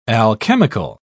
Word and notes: alchemical: British English

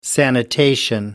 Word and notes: sanitation: American English

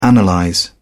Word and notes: analyze: British English

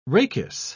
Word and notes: rachis: British English

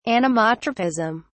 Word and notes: anemotropism: British English

